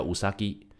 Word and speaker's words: 宇佐木: 所以有的翻译它会翻成弥留之国的爱丽丝，里头的女主角叫做宇佐木柚叶，宇佐木的发音叫乌萨基，那乌萨基的话就是日本兔子的发音。